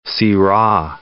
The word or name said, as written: Syrah